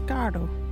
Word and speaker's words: gaardu: ugly word is gado. What is a gado? Gado is playing cards. You can use gado in this sentence. All the woman this one, gado. Gado is spelt G-A-A-R-D-U. Can you say gado?